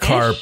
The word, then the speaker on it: carp: Is it crap? Is that a fish? – Carp. Oh, that's carp. Crap.